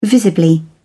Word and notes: visibly: British English

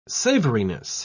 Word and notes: savoriness: British English

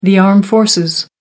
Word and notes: armed forces: American English

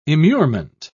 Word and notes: immurement: British English

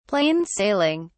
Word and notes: plain sailing: British English

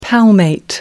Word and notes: palmate: American English

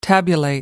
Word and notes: tabulate: American English

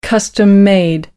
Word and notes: custom-made: American English

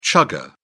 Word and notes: chugger: British English

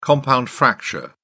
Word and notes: compound fracture: British English

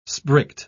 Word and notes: sprigged: British English

